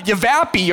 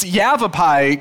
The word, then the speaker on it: Yavapai: It's not Yavapai or something county, it's Yavapai County, and somebody told me it rhymes with have a pie, so it gets me really hungry.